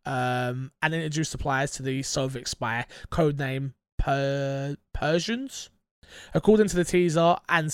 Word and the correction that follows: Perseus: some light googling, Persians, I think I'm saying that right, Persians, I think so, go on. – Perseus, sorry, not Persians.